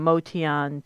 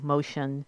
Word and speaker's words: Motian: And the readings I brought, I came across a pretty long rant that Paul wrote about why he changed the pronunciation of his name from Motian to Motion. And people have asked about it quite a bit because I say Modian on the show, and a lot of people know Paul as Motion, Paul Motion.